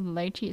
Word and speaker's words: lychee: Another thing, lychee. Lychee. It is lychee. It's not lychee. I'm cringing so much. It is not lychee. It's lychee. It's lychee. Just, it's lychee.